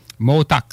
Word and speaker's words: Montauk: Projet Motac. Projet quoi? – Motak. Ça s'écrit M-O-N-T-A-U-K, qui est un nom amérindien qui veut dire, je ne sais pas, je ne m'en rappelle pas.